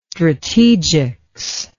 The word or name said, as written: strategics